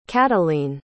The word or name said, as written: cateleen